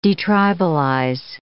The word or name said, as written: detribalize